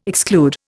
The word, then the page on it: exclude: Indian